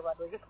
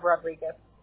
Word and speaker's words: Rodriguez: It's just Lucia Lucia Rodriguez. I'm going to give you my email. Lucia Rodriguez.